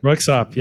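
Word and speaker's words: Röyksopp: And then Royksopp. Isn't that how you pronounce his name? Like Royksopp.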